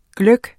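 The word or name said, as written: GLØGGEN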